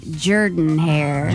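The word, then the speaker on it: Jordan-Hare: As Jerome insists is pronounced Jordan hair.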